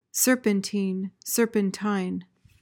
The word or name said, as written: serpentine